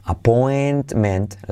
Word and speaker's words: appointment: I have to put off my appointment, appointment, la T de point, casi no se dice.